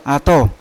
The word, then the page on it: ato: Yawa; Unat